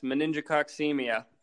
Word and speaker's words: meningococcemia: Do you know how to pronounce it? – Yes, meningococcemia. – That's it.